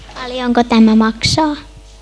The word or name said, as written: paljonko